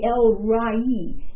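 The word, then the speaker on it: El Roi: Actually, we call it Elroy, but it's really Elrai. It's like L-R-A-W-E-E. But it's easier for us to say Elroy. So that's what we Americans say.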